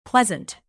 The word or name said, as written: pleasant